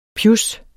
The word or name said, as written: pjus